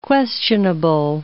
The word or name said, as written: questionable